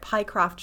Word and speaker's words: Pycroft: This just occurred to me that Pycroft just rhymes with Mycroft, which is kind of ridiculous.